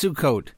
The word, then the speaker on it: Sukkot: Oh, it's pronounced Sukkot.